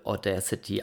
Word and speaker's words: Audacity: Das wird geschrieben Audia City, ähm, aber es wird wohl Audacity ausgesprochen. Ähm,